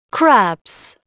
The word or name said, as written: crabs